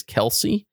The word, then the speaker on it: Kelce: And in their pronunciation guide, it is K-E-L-C-E-E. And according to a couple of reporters around here as well, it is Kelsey. They went to his mother and figured that out.